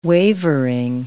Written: wavering

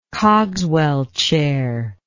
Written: Cogswell chair

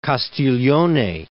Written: Castiglione

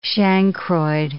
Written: chancroid

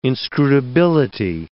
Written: inscrutability

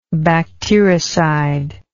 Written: bactericidally

